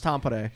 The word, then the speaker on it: Tampere: And then once we got there, she lives in Tampere. It's pronounced Tampere.